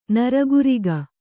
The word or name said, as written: ನರಗುರಿಗ